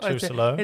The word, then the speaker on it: Trousselot: Well, Navarre Trousselot, am I saying that right? – Yeah, that's right. Trousselot.